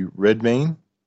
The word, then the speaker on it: Redmayne: played by Eddie Redmayne.